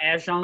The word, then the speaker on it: Etienne: His name is pronounced like Ashon.